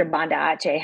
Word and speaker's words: Banda Aceh: Like, and she was at, went in right after Banda Aceh happened.